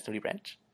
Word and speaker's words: nudibranch: I always assumed that there was some kind of like fancy Latinate pronunciation that made it not literally just nudie branch. – Oh yeah, it's nudie branch.